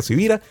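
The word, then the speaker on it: Sibira: Mi nombre es Carlos Javier Civira. Como estoy claro que mi apellido no es tan común, se escribe con S de semana y con B de Victoria.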